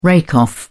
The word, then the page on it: rake off: British English